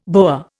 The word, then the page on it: boa: Indian